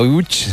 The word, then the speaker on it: Łódź: Aunque se pronuncia Łódź, se escribe Lodz, por si alguien lo busca. – Lodz, sí, Google. Casi no tiene nada que ver la pronunciación.